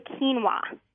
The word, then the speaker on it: quinoa: And then the other one, no one gets this right on the first try, but it's actually quinoa. – Quinoa.